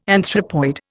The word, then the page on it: anthropoid: Indian